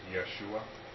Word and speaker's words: Yeshua: It is Joshua, in Hebrew pronounced Yeshua, and then transliterated into the Greek pronounced Jesus.